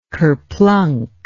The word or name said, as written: kerplunk